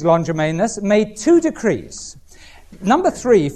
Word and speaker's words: Longimanus: A-R-T-A-X-E-R-X-E-S, Artaxerxes Longimanus, L-O-N-G, I-M-A-N-U-S.